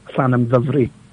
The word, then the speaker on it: Llanymddyfri: Well, the English pronunciation is Llandovery. – Yep. – And then the Welsh is Llanymddyfri.